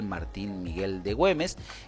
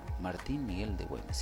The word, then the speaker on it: Martín Miguel de Güemes: Feriado del 17 de junio, fallecimiento de don Miguel Martín Miguel de Güemes. Martín Miguel de Güemes, sí, lo dije bien.